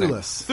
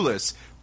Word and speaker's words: Thewlis: I never know how to pronounce his Thewlis. – Name. Thewlis.